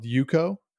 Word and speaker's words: EUCO: There's a big group on social media called Yuko. It's EUCO.US, Yuko. It's pronounced Yuko, but it looks like EUCO.US, but it's Yuko.